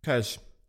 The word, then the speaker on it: Kölsch: This is Kölsch. This is the local beer in Cologne. Kölsch, it's pronounced like Köln, O with an umlaut, is Sorry, I don't know what it is, but it'll be gone soon.